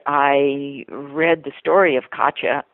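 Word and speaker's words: Katje: And Katya, and that Ch at the end is spelled J-E, because that's how Dutch, that's a diminutive in Dutch.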